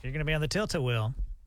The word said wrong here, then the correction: Tilt-A-Whirl: What's a tilt? I didn't say it right. – Tilt-a-whirl.